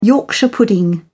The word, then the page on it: yorkshire pudding: British English